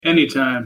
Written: anytime

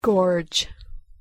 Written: gorge

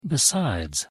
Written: besides

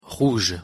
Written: rouge

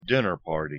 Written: dinner party

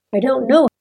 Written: i dont know

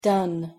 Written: done

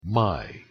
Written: my

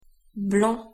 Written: blanc

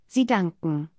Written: sie danken

